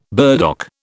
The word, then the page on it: burdock: British English